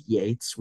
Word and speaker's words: Yeatts: So on all social media, my handle is my name, at Ted Yeatts, which is a weird spelling.